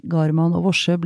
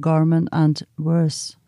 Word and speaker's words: Garman og Worse: Og jeg holdt på å besvime da jeg hørte at Alexander Kiellands klassiske verk Garman og Worse ble uttalt som Garman and Worse.